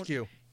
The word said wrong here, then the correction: eschew: I just looked it up, the pronunciation. It's eschew. Sorry.